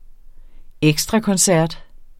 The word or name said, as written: ekstrakoncert